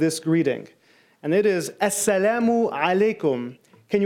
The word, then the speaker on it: Assalamu alaikum: So let's try it one more time. Assalamu, and you're going to go back deep in here. Alaikum, you know that ah sound, like Pee Wee Herman, right?